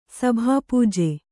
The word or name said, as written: ಸಭಾಪೂಜೆ